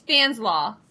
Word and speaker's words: Fanslau: It was close. It's Fanslau. – Fanslau. – Fanslau. – Like coleslaw.